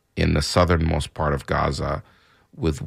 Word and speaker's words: Gaza: In Arabic, it's pronounced Gaza. So if you hear me say it that way, that's just not me having a speech impediment. That's just me saying it in the Arabic version.